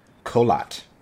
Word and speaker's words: Kholat: Kholat.